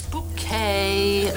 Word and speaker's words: Bucket: It's bouquet. B-U-C-K-E-T. Bouquet. The accent on the second syllable.